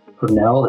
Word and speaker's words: Purnell: And that's J, my name is spelled Purnell. It's P as in Paul, U-R-N-E-L-L at umaryland.edu.